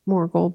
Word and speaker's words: Morgul: Morgul blade. – There you go. I thought you said mortal blade. I'm like, okay, you got it.